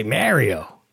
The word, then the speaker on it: Mario: I but see, no, but Michael, but guys, they're Italian, so they say Mario. – But what, Italian, you mean like from Brooklyn? Because I say Mario.